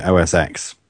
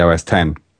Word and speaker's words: OS X: And I was thinking, when at least by renaming it, they'll stop the people pronouncing it wrong, calling it OS X when it's meant to be OS 10.